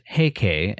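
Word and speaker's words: Heike: While the character for Taira can also be pronounced Heikei,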